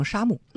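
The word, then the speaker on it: 杉木: 者是山木念成南方用山木，或者也可以念成沙木。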